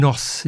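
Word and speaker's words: Gnoss: And Nos is their name. I presume it's a silent G, G-N-O-S-S.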